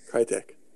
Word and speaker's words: Crytek: I'm more interested in Rise because it's by Crytek. How do you the Crysis guys? I don't remember how to say their name. – Crytek.